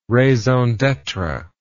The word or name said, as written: raison d'etre